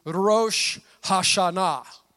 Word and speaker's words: Rosh Hashanah: Now, the, the correct, I believe, the correct pr- pr- pronunciation in the Hebrew is Rosh Hashanah. That's how you got to say it.